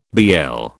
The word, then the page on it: BL: British English